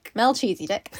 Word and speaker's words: Melchizedek: And I looked up how to pronounce it. – Mel-cheesy-dick.